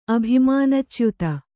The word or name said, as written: ಅಭಿಮಾನಚ್ಯುತ